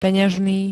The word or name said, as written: peňažný